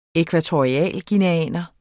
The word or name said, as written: ækvatorialguineaner